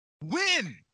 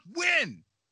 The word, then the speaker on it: Nguyen: James Nygan? – Wynn! Pronounced Wynn! – James Wynn. – James Wynn? – He's Vietnamese. – I know a guy who has that same last name. He pronounces it as Wynn.